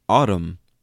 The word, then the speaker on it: autumn: Or autumn. A-U-T-U-M-N. Autumn. Which should be autumn. Autumn. Like there's the N in that word is so unnecessary, you know?